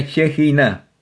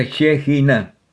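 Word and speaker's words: Shejiná: Así que se pronuncia Shejina, con acento en la última sílaba, y como la J, como Jacinto. Así que se pronuncia Shejina.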